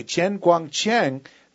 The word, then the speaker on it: Chen Guangcheng: But this story that you're telling about, this Chen Guangcheng, am I saying that right? – That was perfect. – Okay, Chen Guangcheng, he's a hero in China even, because so many Chinese people hate the government's one-child policy.